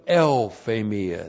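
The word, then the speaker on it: Euphemia (εὐφημία): euphemia. And we've had deuthphamia, now the other word, which is also a hapax legomenon. Euphemia. E-U-P-H, long E-M-I-A.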